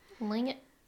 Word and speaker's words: Tlingit: The Tlingit? I don't know if I'm saying that right. I think the T is silent. Tlingit tribe. – Tlingit.